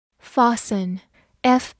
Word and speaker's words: fasten: Fasten，F A S T E N，Fasten。 f 发 f，字母 a 发长音 r，s 发 s，t 不发音，e n 发鼻音嗯，fasten，fasten，美式发音字母 a 读大口 a，fasten 也是可以的。